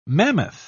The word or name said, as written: mammoth